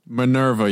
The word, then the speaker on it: Minerva: It's going to be a crazy, crazy case that I've, the Minerva monster is how you say it, right? – Minerva. Yes. I don't know how you were saying it before, but it was fucking dumb.